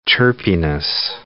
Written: chirpiness

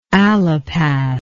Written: allopath

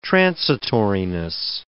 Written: transitoriness

